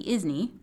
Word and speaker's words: ISNI: Um, the joke is that we nickname the ISNI the ISNI, which rhymes with Disney, um, because it's a dream and a wish your marketing colleague makes when you're fast approaching any contributor deadline.